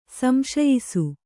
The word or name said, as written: ಸಂಶಯಿಸು